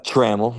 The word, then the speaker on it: Trammell: Trammell, like camel, Trammell.